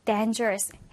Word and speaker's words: dangerous: 자, 강세가 앞에 있고요. dangerous.